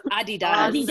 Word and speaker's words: Adidas: But in every other country of the world, it's Adidas. – Adidas. – And Andrew and I were talking about that last night. And he was like, can you please say it like an American? And I'm like, no, it's Adidas.